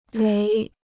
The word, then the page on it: 雷: léi